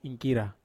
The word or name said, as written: ingkira